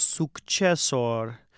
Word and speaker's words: successor: And the pronunciation of successor, you don't have to, I think it sounds really nice to pronounce both of the C's, successor. It's not strictly required, especially when you're singing, it can be difficult to pronounce both of those.